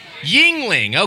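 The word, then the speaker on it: Yuengling: I'm not super into this hoppy, but I got a Youngling on deck. Did I say it wrong? Just one person say it. How do I say it? Yingling. Okay. I said it Youngling like Anakin describing the young Jedi.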